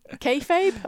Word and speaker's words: kayfabe: It's like you created your own kayfabe.